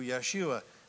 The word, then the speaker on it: Yeshua: With your Jesus glasses on, Joshua is how you say it in Hebrew, Yeshua.